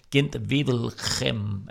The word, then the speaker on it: Gent-Wevelgem: Og så kigger vi frem mod de næste belgiske klassikere, nemlig E3 og Gent-Wevelgem.